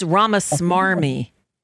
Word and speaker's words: Ramaswamy: You're mispronouncing his name. It's Ramaswamy.